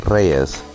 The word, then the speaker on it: Reyes: It's pronounced Paulina Reyes. Yes. Reyes is Spanish for kings.